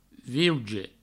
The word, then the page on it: viuge: viuja; vieuge; vieujo